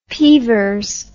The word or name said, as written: peevers